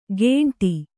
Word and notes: ಗೇಂಟಿ: Kannada

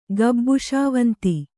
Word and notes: ಗಬ್ಬುಶಾವಂತಿ: kannada

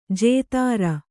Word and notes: ಜತ್ರು: jatru

